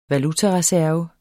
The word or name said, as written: VALUTARESERVEN